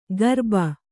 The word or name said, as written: ಗರ್ಬ